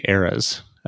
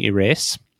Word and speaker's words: Eras: We just started getting them in hand, last time we recorded, but these are the new Blackwing Eras, which I choose to read with a long A. So it's Blackwing Eras. – I still say Blackwing ERAs. – Yeah, the Blackwing ERAs.